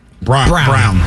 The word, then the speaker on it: Braun: What do you think of Denver giving their rookie, is it Braun or Brown? – Brown. – Christian so it's Brown. Brown, even though it's spelt differently.